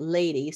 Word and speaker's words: Leidi: His new wife, it's spelled L-E-I-D-I, but you pronunciate it lady.